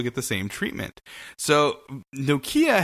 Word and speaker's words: Nokia: How do you say it? – Nokia. that's how the brand is pronounced in the UK.